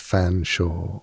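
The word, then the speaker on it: Featherstonehaugh: where a name like Featherstone Shaw is pronounced Fanshawe, or Cholmondeley is pronounced Chumley, or Magdalene is pronounced Magdalene, and Worcester is pronounced Worcester.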